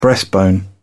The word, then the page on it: breastbone: British English